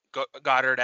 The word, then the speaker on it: Goedert: I think in part because they could have drafted Goedert at uh, it's pronounced Goedert, right? We have we decided Goedert. – That? Yeah, Goedert. yeah.